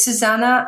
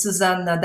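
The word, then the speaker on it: Zuzanna: Yeah, actually, it's Susanna. I'm Polish, and it's Susanna, double Z, double N.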